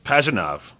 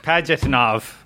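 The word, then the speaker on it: Pajitnov: Tetris creator Alexei, how do you say this? – Pajanov. – Thank you. – Yeah. – Shout out to Russia. – Created. – Oh, God. I'm sorry. I didn't. – Of all the days. – Pajanov. – I didn't think. – Pajanov. Right? That's how you say it.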